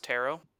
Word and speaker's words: taro: I would just like to say, by the way, that taro is pronounced taro. – It's taro, actually? – No. Actually, I've confirmed it firsthand with Webster's dictionary. Taro is phonetically similar to harrow.